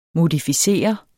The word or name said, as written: modificere